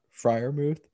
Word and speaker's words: Freiermuth: I mispronounce it? – Freiermuth.